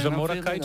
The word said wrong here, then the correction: Jamiroquai: czy jak się jakkolwiek się wymawia Jamie ten zespół. Tak. Ja, naprawdę Jamiroquai, no daj spokój.